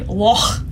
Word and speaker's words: Loch: Yeah, I watched a video of a guy, a Scottish man, complaining about how people say Loch or Loch, but it's really Loch. And he said, pretend like you're actually wading into the Loch, and then when the cold water hits that spot, and the gets caught in your throat, and that's how you say it, the Loch Ness Monster.